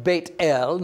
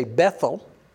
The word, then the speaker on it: Beit El: And he moved from there to the mountain east of Beit El. Now, your Bible may say Bethel. Learn to pronounce that as Beit El.